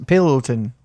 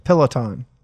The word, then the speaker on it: Peloton: How do you say Pellington? Pellington. – You're saying it wrong so many times. I'm not even going to know if I know how to say it. – Palladian. – Peloton. – Peloton. – There you go. You got it.